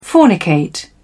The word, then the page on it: fornicate: British English